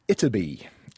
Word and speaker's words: Ytterby: So there was and is, I guess, a small village in Sweden that goes by the name of Itterby. And forgive my Swedish pronunciation there, but it is spelt Y-T-T-E-R-B-Y.